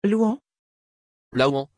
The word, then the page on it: luan: French